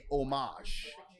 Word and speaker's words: homage: I think it's a French, like, a pronunciation. So in French, you wouldn't pronounce the H. So I think you would say homage. – Homage. – Yes. Homage, so, I think, is how you would pronounce that.